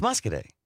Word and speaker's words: Muscoda: What was the town that we thought was Muscadet? – It's Muscadet. It's spelled Muscoda. – Muscoda, yeah.